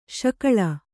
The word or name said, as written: ಶಕಳ